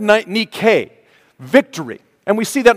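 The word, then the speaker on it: Nike (Νίκη): It is not Nike. Stop them and say, no, it's not Nike, it's Nike.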